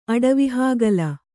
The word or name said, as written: ಅಡವಿಹಾಗಲ